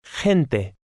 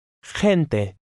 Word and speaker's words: gente: Gente. – Notice how the G-E combination is pronounced. It's somewhat like a breathy H. Now you read number 6. – Gente.